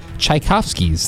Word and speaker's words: Tchaikovsky: On this day in 1882, Tchaikovsky's, that's with a silent T, he got his 1812 Overture debuted in Moscow, Russia.